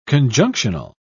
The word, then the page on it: conjunctional: British English